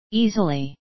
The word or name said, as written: easily